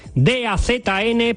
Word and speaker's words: DAZN: DAZN, pero se pronuncia DAZN.